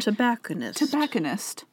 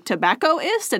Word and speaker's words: tobacconist: Tobacconist. – I was just like, tobaccoist? And it was like, no, we put an N in there to make it easier to pronounce.